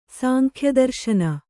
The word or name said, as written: ಸಾಂಖ್ಯದರ್ಶನ